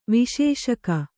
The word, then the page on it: ವಿಶೇಷಕ: viśeṣaka